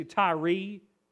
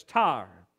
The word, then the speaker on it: Tyre: By the way, some people pronounce that city Tyre, okay? But it's Tyre.